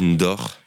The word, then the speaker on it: Ndox: Ouais, non, c'est N-D-O-X mais ça se dit Ndor.